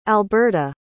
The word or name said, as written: alberta